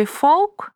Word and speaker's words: folk: Обратите внимание, что в слове «folk» есть буква «l», но она не произносится.